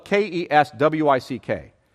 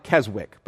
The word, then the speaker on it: Keswick: However, this is probably most like Keswick, spelled K E S W I C K. So sounds like Keswick, but it's pronounced Keswick.